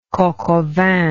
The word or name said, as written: coq au vin